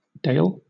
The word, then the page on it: dale: British English